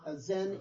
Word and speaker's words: Zen: Essentially, Zen is the Japanese pronunciation of the same character that in Mandarin is Chan.